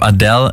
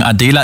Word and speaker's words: Adela: Und nein, ich habe Adele nicht falsch ausgesprochen. Adela ist eine slowakische Singer-Songwriterin, war mal Teil von Cat's Eye, probiert es jetzt solo und das auch sehr erfolgreich.